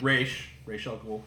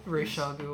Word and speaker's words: Ra's al Ghul: He does look like Ra's al Ghul, but, like... – Ra's al Ghul. – Ra's al Ghul, whatever.